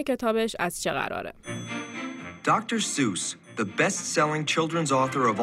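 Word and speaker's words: Seuss: تلفظ اسم دکتر سوس شک و شبهه توش زیاده یه سری میگن دکتر سوس یه سری میگن دکتر زوس و یه عده هم میگن در واقع تلفظ درستش زویس یا سویسه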